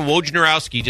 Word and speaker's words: Wojnarowski: Woj is Adrian Wojnarowski, just because I want to say that correctly.